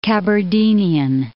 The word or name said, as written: Kabardinian